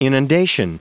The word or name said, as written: inundation